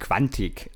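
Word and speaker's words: Quantic: Die großartige Sängerin heißt natürlich Nidia Gongora und äh, Quantik heißt natürlich Quantik. Nicht Quattig, ich bin total bescheuert.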